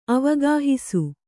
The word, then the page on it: ಅವಗಾಹಿಸು: avagāhisu